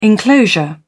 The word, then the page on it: enclosure: British English